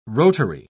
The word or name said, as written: rotary